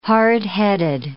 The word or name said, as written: hardheaded